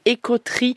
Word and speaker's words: EcoTree: Voilà, toutes les infos pratiques sont sur votre site Ecotri.